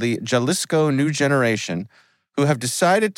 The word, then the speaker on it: Jalisco: It's Jalisco. – Jalisco.